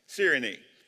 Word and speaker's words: Cyrene: Because the Bible makes it three syllables. So I'm going to pronounce it that way. Cyrene.